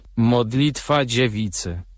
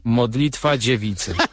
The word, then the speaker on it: Modlitwa dziewicy: Vamos a escuchar la pronunciación en polaco. Escuchen. – Modlitwa dziewicy. – ¿Perdón? – Modlitwa dziewicy.